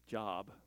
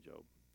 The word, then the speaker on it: Job: Also sometimes pronounced Job, but that's not right. It's actually Job.